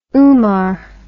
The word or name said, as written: Umar